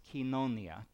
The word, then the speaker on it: koinonia: The word used here is the word that in East Texas Greek is koinonia, and that in the way Greek people, now what do Greek people know about pronouncing Greek, but the way they say it is kinonia.